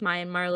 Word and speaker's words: Myanmar: Does Myanmar look right? – Pronounced Myanmar. – Myanmar.